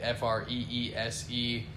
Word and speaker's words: Freese: Freese. Not spelled like Mr. Freeze from the Batman movie, but Freeze, Freese.